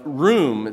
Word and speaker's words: ruwm: The word pride in Hebrew is a primitive root word, and we would pronounce the word in room. It's just simply like the word room. We use the word room. It's actually spelled R-U-W-M.